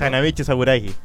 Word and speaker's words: Hanamichi Sakuragi: Hanamichi Sakuragi.